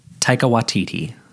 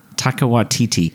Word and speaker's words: Taika Waititi: Taika Waititi. – Taika Waititi.